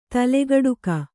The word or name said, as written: ತಲೆಗಡಿಕ